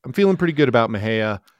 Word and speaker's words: Mexia: Yes, it is pronounced Mejia, named after Jose Antonio Mejia, a Mexican hero for the Republic of Texas Army during the Texas Revolution. – And for our listeners, the it's spelled M-E-X-I-A, but it's pronounced Mejia.